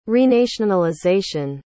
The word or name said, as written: renationalization